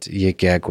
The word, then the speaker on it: Yik Yak: I think the thing you've spoken about that differs between Librex and Yik Yak is that Yik Yak was defined, am I pronouncing it right even? – You're good.